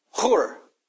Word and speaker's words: Hur: Hur. Here it's pronounced as H-U, so we call him Hur.